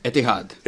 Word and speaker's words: Etihad: Etihad.